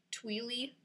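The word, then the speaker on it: Thuyle: How do you pronounce her actual name? – Tweely. Tweely. On Instagram. What? It's XTHUYLE.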